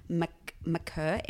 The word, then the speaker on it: Makur: Okay, A-Y-O-R, Aor, McKerr, M-A-K-U-R. – Oh, yeah. – Schwott, okay. C-H-U-O-T.